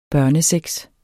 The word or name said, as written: børnesex